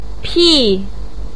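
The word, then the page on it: pi4: tone 4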